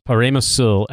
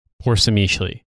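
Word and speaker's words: Przemyśl: And for the record, the fortress is actually pronounced Paremosul and not, emphatically not, Porsimishli.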